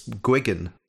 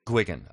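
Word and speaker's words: Gilgun: I think it's Gwiggin. – Gwiggin, okay. – Is how it's pronounced.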